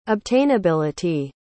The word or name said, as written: obtainability